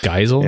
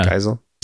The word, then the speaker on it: Geisel: Yeah, it's Theodore Zeus Geisel or Goozel or Guisel. – Oh, that's right. – I don't know how to say it. – Geisel, yeah. Maybe? Yeah. – Geisel.